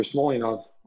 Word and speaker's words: Smolyanov: Victor Smolyanov.